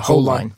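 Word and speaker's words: Hollein: Holine.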